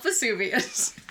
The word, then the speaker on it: Vesuvius: V-E-S-U-V-I-U-S.